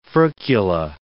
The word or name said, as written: furcula